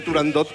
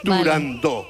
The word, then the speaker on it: Turandot: pues yo también lo digo mal y digo turandot como todo el mundo. Turandot. Pero para que quede claro... – Entonces, todos tontos ya. – Para que quede claro, es turandot.